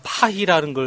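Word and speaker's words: Paris: 그냥 파리는 R은 흐 발음을 하기 때문에 파리도 파희라고 하고 우리가 말하는 로댕도 호당이라고 하는 거예요.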